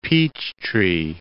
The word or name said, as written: Peachtree City